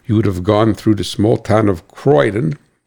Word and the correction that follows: Corydon: Corydon, I think it's pronounced Corydon, C-O-R-Y-D-O-N, I always pronounce it wrong. Corydon, East Penn, who manufactures Deca batteries, has a large facility there right next to the highway.